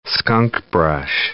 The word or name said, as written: skunkbrush